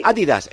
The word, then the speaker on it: Adidas: Se pronuncia Adidas. En Adidas. Alemania decís Adidas. – Sí, sí. – En inglés lo dicen así.